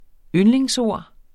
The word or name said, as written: yndlingsord